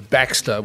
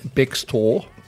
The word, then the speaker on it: Bextor: Well, I am pleased that you have pronounced the second part of her surname correctly, because I do hear a lot of people call her Baxter, which I don't like. I like to see it's Bextor, not Baxter.